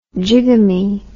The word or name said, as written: jigamy